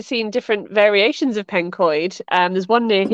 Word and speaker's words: Pencoed: And it's a very simple one, but everybody who lives there pronounces it wrong. It drives me a little bit crazy. And that's Pencoed. – Oh, right, Pencoed. – Oh God, when people call it Pencoed, I just want to scream.